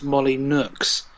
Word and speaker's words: Molyneux: I actually met Peter Molyneux once, or as he's supposed to be called, as he told me, his actual name is pronounced Molly Nooks.